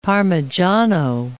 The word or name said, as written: parmigiano